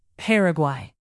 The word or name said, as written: paraguay